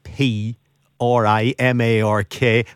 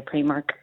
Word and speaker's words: Primark: The store in Belfast that lost its premises yesterday, beginning with P. R-I-M-A-R-K. How do you pronounce that? – Definitely Primark. – That's same with me. It's definitely Primark.